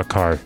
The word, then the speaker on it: Kerr: Se escribe Kerr, pero se pronuncia Carr por alguna razón.